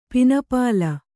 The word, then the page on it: ಪನಿಪುಲ್: pani pul